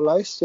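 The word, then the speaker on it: Lohse: How do you pronounce that surname? – L-O-H-S-E. L-O-H-S-E, isn't it? Yeah, Loesser. – Yeah, that's right. Yeah, Loesser. It's Loesser. Yeah, that's probably right.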